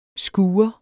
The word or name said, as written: skure